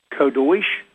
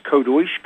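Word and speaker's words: Kadosh: And I'm giving them to you phonetically so that they're easier to remember. But it's Kodosh, Kodosh, Kodosh, Adonai, Sabayot.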